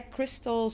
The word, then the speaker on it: Kristel: So yeah, Kristel Zweers. I don't know. Can they find my name somewhere? Kristel. – Yeah, yeah, yeah. We'll spell it. – Yeah. – K-R-I-S-T-E-L.